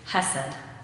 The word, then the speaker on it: hesed: The Hebrew word for faithfulness from God and among the community is hesed. The word hesed, only you pronounce it with a little more of that back-of-the-throat sound that's in Hebrew that I can't quite pull off.